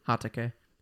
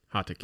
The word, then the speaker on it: Hatake: What is it? – Hatake. – Hatake. – But it's spelled this. – Hatake. – Yeah. – H-I-T-A-K-I? – H-A-T-A-K-E. Oh. Hatake.